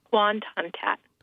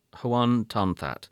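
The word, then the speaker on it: Hoan Ton-That: Hoan Tan That? – Hoan Tan That. – Hoan Tan That.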